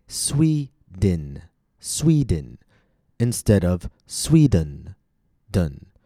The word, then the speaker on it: Sweden: Sweden has two syllables, Sweden.